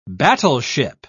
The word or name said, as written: battleship